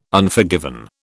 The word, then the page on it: Unforgiven: British English